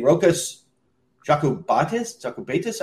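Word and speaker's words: Rokas Jokubaitis: Rokas Jakubaitis, I believe it's pronounced, Lithuanian.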